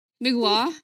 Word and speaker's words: Mgła: If I look at what I listened to last week, here goes: Riot, The Privilege of Power, Dragony, because I had to, Mgła, because we have a friend from Poland and he taught me how to pronounce this band's name.